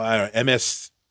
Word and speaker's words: MSCH: Called MSCH. It's like Mischief, but it's spelled weird.